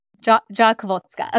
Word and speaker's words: Rzadkowolska: Rzadkowolska.